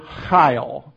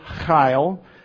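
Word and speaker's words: chayil (חיל): And the word for valiant men, some of you again will recognize, it's the word chayil. It's the Hebrew word chayil, and it's spelled H A Y I L.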